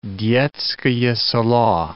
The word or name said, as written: Detskoe Selo